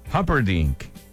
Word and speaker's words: Humperdinck: Humperdinck.